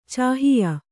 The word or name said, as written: ಚಾಹಿಯ